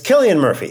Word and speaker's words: Cillian Murphy: how do I say this name? – I believe it's Cillian. – All right, it's Cillian Murphy. That's what we're going to go with.